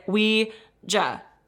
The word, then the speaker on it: Ouija: I'm going off of, I had a Hasbro glow-in-the-dark Ouija board as a child, and the box said to pronounce it Ouija, so that's how I am saying it.